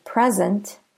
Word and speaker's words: present: If you stress the first syllable in present, it is either a noun, a gift, or an adjective, opposite of absent. If we stress the second syllable, however, it becomes a verb, to offer. So that would be present, as opposed to present.